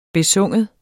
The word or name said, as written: besynge